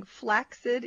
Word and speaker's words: flaccid: But they also I used it wrong. Flaccid is what they say is the way to pronounce it.